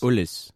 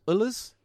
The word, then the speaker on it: Elwes: Is it, how do you pronounce it? E-L-W-E-S. Ulls? – Ulls. – Ulls? Like, you know, Carrie Ulls, the actor who's in Saw and Robin Hood Men in Tights.